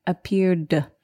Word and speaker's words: appeared: So at the end of appeared, the ed is pronounced just like d, appeared.